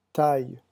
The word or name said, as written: taille